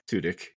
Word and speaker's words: Tudyk: In a follow-up from the last episode that we have, Alan, and again, I can't say it, Tudyk, isn't that how you say it? – Tudyk.